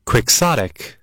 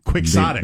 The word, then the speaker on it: quixotic: chaotic, uh, which means exceedingly idealistic, unrealistic, impractical. Quixotic. Ah, I said it wrong. – Quixotic.